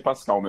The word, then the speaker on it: Pascal: É, é Pascal, né? A, sua, a pronúncia Acho dele. Acho que sim. – Acho que sim. Procurei aqui e achei Pascal mesmo é, que é um dos novos queridinhos de Hollywood.